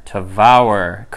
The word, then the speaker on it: Tavour: Tavour. Correction. Paul. Time for a correction. – We realize we've been pronouncing it wrong.